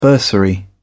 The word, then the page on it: bursary: British English